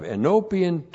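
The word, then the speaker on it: Enopion (ἐνώπιον): And then we're going to have enopion two times here. Enopion. E-N, long O-P-I-O-N. Enopion.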